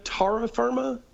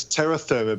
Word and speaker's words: Terra Firma: Do you say Tara Firma? Do you know the expression Tara Firma? And would you pronounce it that way? – It's Terra Firma because there's an E in Terra Firma. – Oh, that's right.